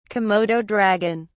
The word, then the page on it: komodo dragon: British English